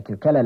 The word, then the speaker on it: Kukele: Kukele, that's spelled K-U-K-E-L-E, Kukele.